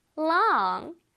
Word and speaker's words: long: long 할 때 롱롱롱이 아니라 혓바닥이 살짝 나와야 되겠죠. L이니까 long long.